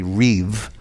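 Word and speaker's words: rîb: It's spelled, well, the English transliteration is R-I-B, or it sounds like rib, but it's really reeve.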